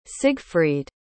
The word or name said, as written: sigfried